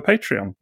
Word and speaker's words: Patreon: And we also have a bit of a Patreon. Now, I'm saying that correctly tonight, aren't I? – That was perfect. Absolutely perfect.